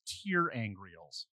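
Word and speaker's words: ter'angreal: It's Terangreals. – Thank you. Thank you for that pronunciation.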